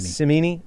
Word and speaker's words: Cimini: Is it Rich Simini? Is that how you pronounce Cimini. – It? Cimini.